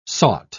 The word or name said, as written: sought